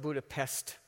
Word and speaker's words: Budapest: Berlin, Budapest, it's spelled Budapest, but it's pronounced Budapest, and Moscow.